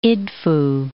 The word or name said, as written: Idfu